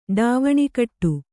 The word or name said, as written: ಡಾವಣಿಕಟ್ಟು